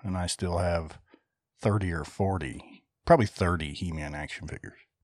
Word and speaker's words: Hemann: H-E-M-A-N-N.